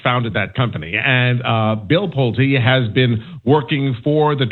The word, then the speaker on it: Pulte: Can you tell us who the hell Bill Pulte is, if I'm even saying that right? – Yeah, Pulte.